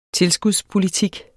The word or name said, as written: tilskudspolitik